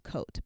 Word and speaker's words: Cote: I think it's pronounced Cote, but it's C-O-T-E.